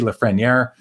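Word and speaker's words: Lafrenière: I got to remember how to say it. It's Alexis Lafreniere.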